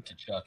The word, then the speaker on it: Tkachuk: So Matthew Tkachuk is annoying as hell too. This division's gonna be fun. – His name does not. You don't pronounce the T. It's Tkachuk.